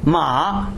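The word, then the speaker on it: ma (马): It's ma. Ma. Ma. Now listen to this. Mama, qi ma ma, man ma ma ma. It's a story. It's a story. – Horse. – Mama means mother. Mama, right? – Mama.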